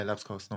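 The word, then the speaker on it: Labskaus: Und jetzt weiß ich nicht, ob ich es richtig ausspreche. Äh, äh Lapskaus? Kaus? Lapskaus? – Ja, ja, Lapskaus, normal.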